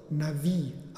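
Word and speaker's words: Navi (נביא): Das hebräische Wort für Prophet lautet nämlich Navi. Es wird zwar mit Bet, einem B-Laut, geschrieben, aber dieser wird zum W hin gesprochen. Deshalb wird das Wort auch Navi ausgesprochen. Erinnert das nicht an etwas ganz anderes?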